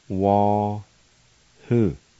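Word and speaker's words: wahu: Number five, screech owl, wah-hoo, wah-hoo, wah-hoo.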